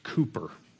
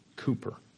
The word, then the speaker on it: Cowper: Um, this, this man, William, it's, uh, his name is actually pronounced Cooper. Cooper.